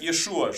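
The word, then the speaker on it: Иешуа (ישוע): И действительно, имя Иисус по-еврейски произносится как Иешуа, что означает «Иегова спасает» или «Господь спасает».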